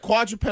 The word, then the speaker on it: quadruped: When you said quadruped, I was like, okay, something with four legs. – I said quadruped, I think. – Yes, you did. – Because that's how it's spelled.